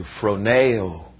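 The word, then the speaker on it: phroneo (φρονέω): It's a Greek word pronounced pretty close to phroneo.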